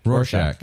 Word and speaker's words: Rorschach: Am I saying that correctly? – Rorschach? – Say it again. – Rorschach. – Okay, yeah. Rorschach.